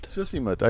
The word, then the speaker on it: Sisimiut: Sisimiut. – Sisimiut.